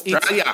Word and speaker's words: Australia: Australia. – There's no A. There's no L. It's Australia.